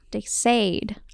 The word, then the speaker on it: Sade: It's not de Sade. – It sounds better that way, Sade. – Though. – De Sade. Yeah. – I feel like de Sade is like the colloquial version of it.